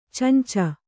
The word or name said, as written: ಚೊಂಚು